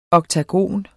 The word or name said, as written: oktogon